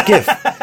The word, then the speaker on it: GIF: It's GIF. Okay. So graphic exchange interform, it's a hard G.